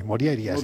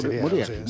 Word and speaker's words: Morjaria: Bueno, la J normalmente se pronuncia como I, Morjaria. – Moriaria.